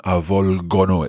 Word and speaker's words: avvolgono: And also, just noticing the form of it in the loro form, avvolgono, it's a hard G sound there. So avvolgere, but avvolgono, we keep the hard G.